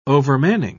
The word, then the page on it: overmanning: British English